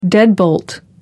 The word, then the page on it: deadbolt: American English